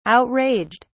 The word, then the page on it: outraged: British English